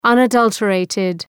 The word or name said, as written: unadulterated